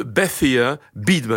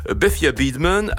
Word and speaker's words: Bethia Beadman: Bethia Beadman. Alors Bethia s'écrit B-E-T-H-I-A et Beadman, B-E-A-D-M-A-N, au cas où certains voudraient se lancer dans une enquête, parce qu'il y a matière à le faire.